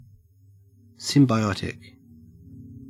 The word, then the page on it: symbiotic: Australian English